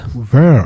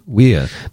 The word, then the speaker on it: Wehr: How would you pronounce Wehr? – Wehr. – All right. Interesting. Wehr. So it must be German. – Maybe even Wehr. But right. Depends whether you want to say it Germanically or not, I guess.